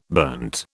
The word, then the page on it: burnt: British English